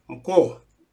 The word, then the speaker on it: Mko: Mko, bear. Mko. Some people pronounce it mko. Mko.